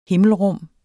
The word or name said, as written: himmelrum